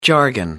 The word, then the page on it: jargon: American English